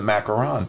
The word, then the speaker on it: macaron: And Italians, passionate about his and Italians pronounce it macaron.